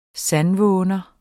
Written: sandvåner

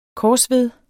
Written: korsved

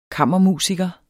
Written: kammermusiker

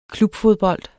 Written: klubfodbold

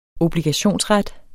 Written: obligationsret

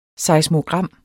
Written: seismogram

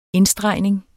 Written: indstregning

